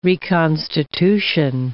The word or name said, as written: reconstitution